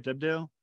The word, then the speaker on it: Debdale: In the book, it feels like between Catholicism and Protestantism, with Dee on the one side and Debdale, am I saying his name right? Debdale? – Mm-hmm.